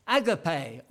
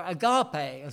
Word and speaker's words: agape: It's used 250 times in the New Testament, and it's a word that we often hear bandied about, agape, or agape, as some people pronounce it, agape.